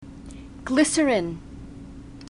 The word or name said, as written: glycerin